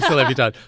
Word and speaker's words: Celebutard: And I hate to be that guy, but the other lipstick was Celebutard. – Oh, I'm saying it wrong. – Yeah, Celebutard. So as in celebrities.